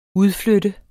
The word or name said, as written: udflytte